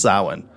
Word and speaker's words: Samhain: I've been saying Samhain like forever, but Samhain is pronounced Samhain, but it is spelled Samhain.